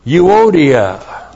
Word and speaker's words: euodia: We keep on being, every one of us as royal ambassadors for Jesus Christ, we keep on being euodia. E-U long O-D-I-A. Euodia.